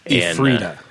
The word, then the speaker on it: Ephrata: So what we did was we flew into Spokane and then we drove to Ephrata, which I finally am pronouncing it correctly. – How do you spell that? – E-P-H-R-A-T-A. – Ephrata. – Ephrata. – Ephrata. – Yeah. – Okay. – But I kept wanting to call it Ephrata, and they said in Pennsylvania it's Ephrata.